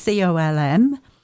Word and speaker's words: Colm: So it is Colm, which is Colm, and then Mac, Mac, Con, Con, and then Iomaire, and it is called the Finnish line, but as I say, Finnish is as in somebody from Finland.